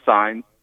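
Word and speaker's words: Saenz: It's pronounced sign, like okay. A street sign.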